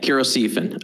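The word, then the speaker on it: cheirosiphon: I noticed that not everyone reads the outline because I did look this up, and I looked at four different pronunciation websites and one primary literature article that told me the English pronunciation. And Kurosifan. The Age of Noob does it very well because he adds a little bit of in the H, you know, but I'm very American.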